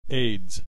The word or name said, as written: AIDS